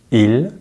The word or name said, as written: il